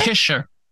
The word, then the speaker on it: pisher: Pisher is spelled P-I-S-H-E-R. Pisher.